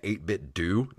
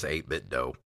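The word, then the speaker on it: 8BitDo: So I think it's just 8-bit dough. – And yeah. – That's how I'm going to pronounce it.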